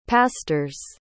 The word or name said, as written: pastors